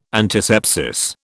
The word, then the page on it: antisepsis: British English